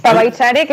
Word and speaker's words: Pabaichare: Hemos comenzado esta entrevista con Rabudiciu, una de esas canciones que está en el álbum Paiva Chari, ¿no? Si no lo he dicho malamente. – Pabaichare, que quiere decir para bailar.